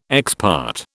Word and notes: ex parte: British English

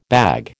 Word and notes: bag: American English

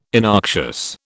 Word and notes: innoxious: British English

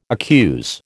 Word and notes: accuse: American English

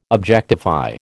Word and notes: objectify: American English

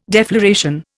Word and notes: defloration: Indian

